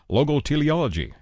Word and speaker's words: Logoteleology: Logoteleology. Did I get close, Luis? – Yes, you did.